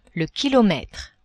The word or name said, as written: kilometre